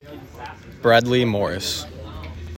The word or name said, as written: Bradley Morris